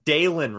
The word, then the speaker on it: Dylan: It's apparently Dalen Wright. – Oh, wow. – But his name is spelt like Dylan, D-Y-L-A-N.